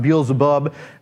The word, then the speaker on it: Beelzebub: Sometimes it's pronounced Beelzebul, sometimes it's pronounced Beelzebub.